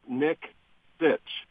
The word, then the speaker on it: Nicksich: Okay, it's Nicksich. It's just as it's spelled. Sitch without the T's, I say.